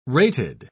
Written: rated

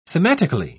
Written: thematically

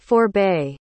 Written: forebay

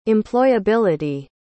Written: employability

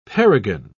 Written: paragon

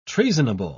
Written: treasonable

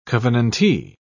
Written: covenantee

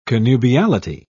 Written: connubiality

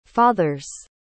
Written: father's

